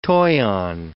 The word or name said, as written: toyon